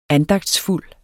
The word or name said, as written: andagtsfuld